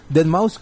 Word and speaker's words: Deadmau5: Deadmau5, que se escribe con un 5 al final, ¿no? En vez de ese.